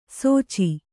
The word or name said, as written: ಸೋಚಿ